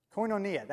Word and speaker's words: koinonia: Koinonia, that's it.